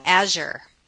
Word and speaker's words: Azure: I always say Azure. Rhymes with badger.